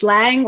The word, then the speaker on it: Lange: Oh, please don't say Lange when you speak English. It's Lang when you speak English. And yeah, it's indeed Lange when you speak German. But I don't like these, you know, German pronunciation in English. You know, this is very ambitious. So it's perfect if you say Lang.